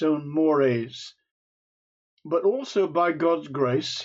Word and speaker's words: mores: I'm not a sociologist, but it's a word that's used, and it looks like mores, M-O-R-E-S, but it's pronounced mores.